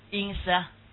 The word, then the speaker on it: encephalitis: 怎么读？right，这叫 encephalitis，读 litis，关于脑的一种疾病，什么东西啊？